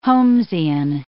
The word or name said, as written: Holmesian